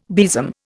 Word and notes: besom: Indian

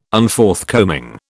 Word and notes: Unforthcoming: British English

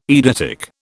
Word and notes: eidetic: British English

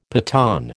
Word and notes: Pathan: American English

